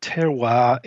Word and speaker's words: terroir: Terroir sounds all fancy because of the way you pronounce it in French.